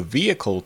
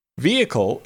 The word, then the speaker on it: vehicle: Vehicle is three syllables. Vehicle. Note the long E at the beginning, as well as the silent H. Vehicle.